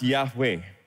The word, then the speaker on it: Yahweh: So this is pronounced Yahweh.